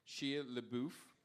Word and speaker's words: Shia LaBeouf: This is Brad Pitt, Shia LaBeouf.